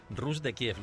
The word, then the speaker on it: Rus de Kiev: Llegaron hasta Constantinopla y crearon el reino Rus de Kiev, ¿lo he dicho bien? – Perfectamente.